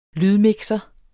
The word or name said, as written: lydmixer